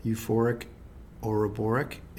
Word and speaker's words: Euphoric Ouroboric: We're going to be talking about your new record, Euphoric Ouroboric, in a minute. But first, let's. Did I pronounce that correctly? – That was perfect.